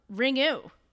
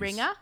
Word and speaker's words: Ringu: Not actually apparently pronounced like that. – How do you pronounce it? – That's the Americanization. I guess the U is not long, that vowel in Japanese. – Ringa? – I think it's ringa.